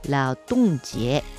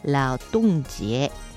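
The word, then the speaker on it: 劳动节: 労働節はラオトンジエ、こんな風に発音します。ラオトンジエ、これが 5 月 1 日メーデーの祝日になります。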